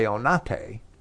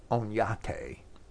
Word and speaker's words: Oñate: It is commonly known that Juan de Onate, perhaps that should be pronounced Onyate, was a Spaniard of Jewish descent from his mother, as even Wikipedia acknowledges.